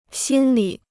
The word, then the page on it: 心理: xīn lǐ